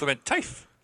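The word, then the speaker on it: Klementieff: Oh God, I'm gonna butcher her name. Pom Clementif. – Clementif. – I was close.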